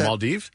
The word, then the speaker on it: Maldives: So from there, we're going to go to the Maldives. Is that how you pronounce it? – The Maldives? – Maldives, wow.